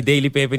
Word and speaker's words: Daily Paper: Voor de mensen die, die Daily Paper niet kennen.